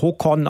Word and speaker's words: Håkon: Ich lerne nebenbei Norwegisch und weiß mittlerweile, dass der jetzige König, der Håkon geschrieben wird, Håkon ausgesprochen wird, der Sohn von Harald.